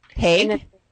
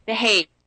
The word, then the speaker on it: The Hague: She's been all the way to China and presented, and we're hoping that she is going to, now help me say, is it like Hague or Hog? How do you say the word? – Yeah, I believe it's Hague. – Hague? Okay, say it again. – The Hague.